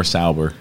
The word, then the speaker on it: Sauber: I think it's more Sauber.